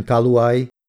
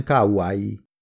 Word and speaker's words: Kauai: A helyes kiejtés Hawaii-i, majdnem szótagolva és az i betűt külön kiejtve, ugyanígy külön kell kiejteni szinte minden egymást követő magánhangzót, tehát nem Kauai, a legnyugatibb sziget neve, hanem Kauai.